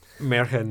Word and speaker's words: Märchen: 1943 Kai, March and Maze. I probably mispronounced that. – Märchen Maze. Okay. I am not German.